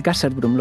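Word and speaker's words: Gasherbrum: El Gasserbrum, lo digo bien, ¿verdad? Sí, lo dices correcto.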